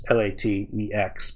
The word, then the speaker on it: LaTeX: The completely opposite approach that people use, and people who do this more seriously tend to do it this way, is they use LaTeX, which is LaTeX, but it's pronounced with a K at the end.